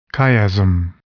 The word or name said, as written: chiasm